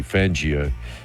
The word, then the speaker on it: Fangio: Thank you, Fangio.